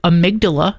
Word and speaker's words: amygdala: And it also is attached to the amygdala.